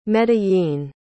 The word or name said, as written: medellín